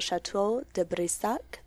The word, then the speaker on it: Château de Brissac: The castle's design is based on a real location called Château de Brissac, the former home of the nobles of the French aristocracy. And I don't know if I pronounced that right. – Yeah, you did.